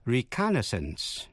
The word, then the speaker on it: reconnaissance: Phân biệt cách đọc của chữ reconnaissance, R-E-C-O-N-N-A-I-S-S-A-N-C-E, reconnaissance. Reconnaissance, a reconnaissance mission, là một đặc vụ thám thính, viết tắt là recon, R-E-C-O-N.